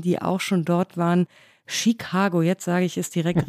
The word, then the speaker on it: Chicago: habe ich falsch ausgesprochen, nämlich mit einem viel zu starken T, also Chicago habe ich gesagt. Also ich habe es sehr deutsch ausgesprochen in diesem deutschen Podcast, aber natürlich äh, jeder, der die Stadt so liebt, muss sie auch richtig aussprechen.